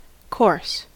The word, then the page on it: coarse: American English